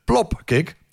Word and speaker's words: plopkick: Ze gebruikten met de happy hardcore, gebruikten ze de zogenaamde, dan moet ik het heel goed uitspreken, anders lijkt het op, plofkip, anders lijkt het op, eh, op plofkip, de plopkick.